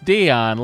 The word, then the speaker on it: Dion: Is pronounced Dion, like the popular 90s singer Celine Dion, but in parentheses, Mariah Carey.